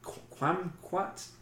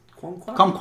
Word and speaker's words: kumquat: quamquat? – Kumquat.